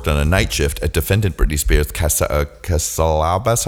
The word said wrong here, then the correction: Calabasas: How do you pronounce that, Brandy? Calabasas. – Oh, Calabasas. Britney Spears' Calabasas residence at approximately 11:30 p.m.